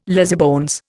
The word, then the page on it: lazybones: Indian